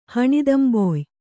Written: ಹಣಿದಂಬೊಯ್

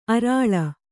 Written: ಅರಳು

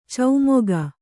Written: ಚಮಕ

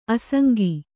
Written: ಆಸಂಗ

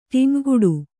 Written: ಟಿಂವ್‍ಗುಡು

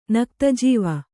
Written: ನಕ್ತಜೀವ

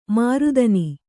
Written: ಮರುದಿನ

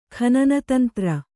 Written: ಖನನತಂತ್ರ

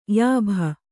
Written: ಯಾಭ

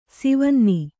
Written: ಸೀವನ್ನಿ

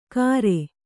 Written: ಕಾರೆ

